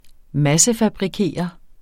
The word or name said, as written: massefabrikere